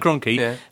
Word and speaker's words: Kroenke: A, because they won a game, and B, because Stan Kroenke has launched this take... Have I said that right, Owen? – Yeah. – Stan Kroenke has launched his takeover bid.